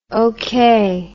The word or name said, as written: okeh